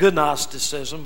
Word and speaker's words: Gnosticism: The word is spelled G-N-O-S-T-I-C-I-S-M. It looks like Gnosticism, but it's pronounced Gnosticism.